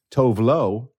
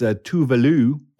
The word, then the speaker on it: Tove Lo: Just recently in the news, there was a story of the Swedish singer, which I have always thought was Tove Lo, T-O-V-E. – Yeah. – First name, last name, Lo, L-O. Apparently it's Tuvalu, because it's Swedish.